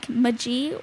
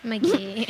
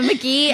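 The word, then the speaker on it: Maniac Magee: And I'm not sure if this is pronounced Maniac Maji or Meiji or McGee. – Yeah, McGee.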